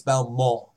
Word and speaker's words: Valmont: And they say Mont, but I guarantee you it's Valmont. with the silent T.